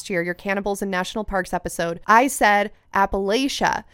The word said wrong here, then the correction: Appalachia: and somebody from this area in the U.S. commented and said it's Appalachia, Appalachia, and then I looked into it, and that is the way that locals say it.